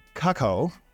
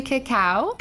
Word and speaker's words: cacao: It has vodka, creme de cacao. Did I say that right? – I don't think that's right. – I would right. – say creme de cacao.